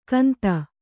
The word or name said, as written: ಕಂತ